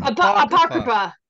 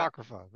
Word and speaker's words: Apocrypha: Apocrypha. Apocrypha. – Apocrypha.